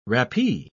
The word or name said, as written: rappee